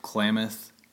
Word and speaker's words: Klamath: Yeah, so the Native Americans actually saw this eruption happen, and it says one Klamath, yeah, that's how we pronounce that word.